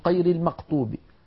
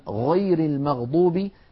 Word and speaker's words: غير المغضوب: يعني مثلا بعض أهل تلك المناطق يقولون قير المقطوب، قير المقطوب بالفاتحة بدلا من غير المغضوب، يشوبون صوت الغين بشيء من القاف.